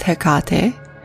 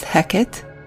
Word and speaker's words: Hecate: There is Hecate, there is Hecate, and then there is Hecate. Same goes for the spelling of her name. Many people spell it H-E-C-A-T-E, and others spell it H-E-K-A-T-E.